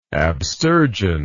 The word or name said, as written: abstergent